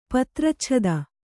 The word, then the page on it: ಪತ್ರಚ್ಛದ: kannada; patracchada